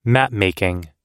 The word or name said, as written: mapmaking